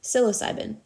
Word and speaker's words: psilocybin: Psilocybin. Psilocybin.